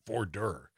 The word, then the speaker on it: voir dire: voir dire, if you will, Dom, on Friday. I'm sure I'm saying that right, voir dire.